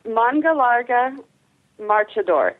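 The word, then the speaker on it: Mangalarga Marchador: Okay, Manga Larga Marchador, and it's spelt just as it sounds. – Manga Larga Marchador, and is it?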